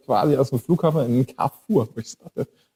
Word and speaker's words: Carrefour: Spricht man die? Ich habe immer Carrefour dazu gesagt. Okay, mein Französisch. Also Carrefour.